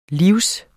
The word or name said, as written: live